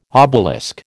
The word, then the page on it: obelisk: American English